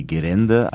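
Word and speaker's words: Gerende (ゲレンデ): Ausgesprochen wird das eher wie Gerende, also R für L, und das bedeutet tatsächlich Skipiste.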